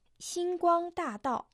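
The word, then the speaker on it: Синьгуангдадао (星光大道): Дорожка звезд по-китайски звучит так. Синьгуангдадао. Иероглиф «синь» в китайском языке произносится в первом доне. В переводе на русский это «звезда». Гуанг также в первом доне. Свет, лучи. Та – четвертый тон. Большой, великий. Тао – также четвертый тон.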